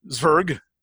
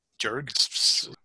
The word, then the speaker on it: Zwerg: And here you're going to have to help me because I always mispronounce German. Zwerg. Z-W-E-R-G. Jerg. – No, I think you had it right the first time.